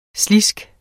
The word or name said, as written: sliske